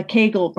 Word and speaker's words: Kegel: The Kegel rhymes with bagel.